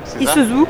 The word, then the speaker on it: Isuzu: Isuzu.